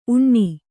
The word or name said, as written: ಊನ